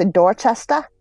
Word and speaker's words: Dorchester: I think she was actually heading back to New Hampshire because Dorchester, or, you know, as locals say, Dorchester, is way south of Revere.